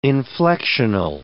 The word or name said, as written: inflectional